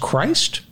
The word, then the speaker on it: Christ: Is it pronounced Christ? – Yes, it is.